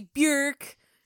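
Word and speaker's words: Björk: It's not Bjork. – And it's not really Bjork. – It's Bjork.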